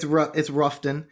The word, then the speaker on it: Rutherfordton: Yeah, it's Ruffton. – Ruffton, Ruffton, Relafordton, depending on what part of the county you live in.